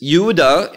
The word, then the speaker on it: Yehudah: And Judah, this is Yehudah in Hebrew, that's how we pronounce it.